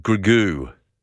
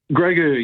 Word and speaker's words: Grogu: A Mandalorian and, how do you pronounce it, Grogu? – Grogu, yeah, Grogu.